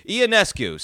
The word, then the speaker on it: Ionescu: Is that how you say her last name? I know Ioascu. I know Ioascu. I'm butchering her name. Let me get a pronunciation. That's so disrespectful to the. I am a misogynist for saying that name wrong. How do you say it? How do you say? Here we go. Oh, my computer's silent. Ionescu. So, I was way off.